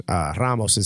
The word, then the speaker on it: Ramos: You know, I remember Todd Ramos or Ramos. Ramos is how you pronounce it in Spanish.